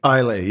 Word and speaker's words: Islay: Now, the first thing you do is impress your friends by knowing how to pronounce the word Islay. – Islay, yes.